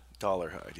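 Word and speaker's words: Dolarhyde: Dollarhide, yeah.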